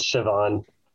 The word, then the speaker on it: Shivan: Yeah, my name is Shivan. Okay. It kind of sounds like Sean with a V in it.